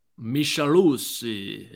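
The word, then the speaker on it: Micheluzzi: Michaeluzzi. Hey, I think you got to say it like that. He's Italian.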